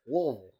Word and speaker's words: Louisville: That's how you say it. Louisville.